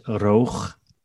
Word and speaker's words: Roog: His name is Roch. That's how you pronounce it. – Let me try. Roch. – Yeah. So it's R-O-O-G. Roch. So probably Roog is maybe the way Americans pronounce it.